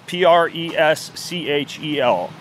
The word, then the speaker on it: Preschel: P R E S C H E L.